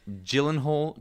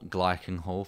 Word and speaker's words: Gyllenhaal: Jake Gyllenhaal, Glykenhall, for you Scots out there who can't pronounce words correct.